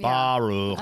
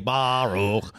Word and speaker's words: Baruch: Baruch, B-A-H-R-U-C-K, Baruch, C-H.